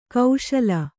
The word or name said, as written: ಖುಶಾಲಿ